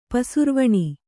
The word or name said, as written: ಪಸುರುವಣಿ